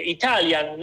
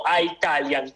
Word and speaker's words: Italian: Belén Silva nos dice un comentario hermoso respecto a la, la pronunciación de Italian en inglés, que es a Italian, sería algo así como este, Italian, no a Italian, como dice el jefe Gorgori.